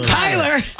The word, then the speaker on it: Kyler: If Tyler Murray, Kyler.